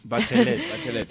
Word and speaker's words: Bachelet: Que el 11 de marzo del año 2006, ya es el siglo que estamos, la política socialista Michelle Bachelet, o pronunciación mal o bien, me decís. – Bachelet.